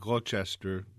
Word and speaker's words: Gloucester: And of course, it's spelled Gloucester, but it's Gloucester, like Worcestershire and all that weirdness.